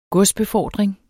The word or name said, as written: godsbefordring